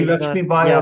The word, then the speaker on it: Lakshmibai: There is also some other key figures in this rebellion I want to talk about, and that is, I'm going to try to say his name right here, Rani Lach. – Rani Lakshmibai of Jhansi. – Yes. Thank you for correcting me there.